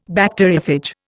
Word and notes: Bacteriophage: Indian

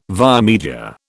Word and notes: via media: British English

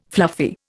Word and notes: fluffy: Indian

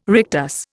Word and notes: rectus: Indian

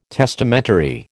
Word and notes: testamentary: American English

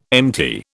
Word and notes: empty: British English

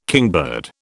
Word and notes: kingbird: British English

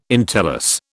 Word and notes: entellus: British English

